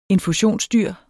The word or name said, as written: infusionsdyr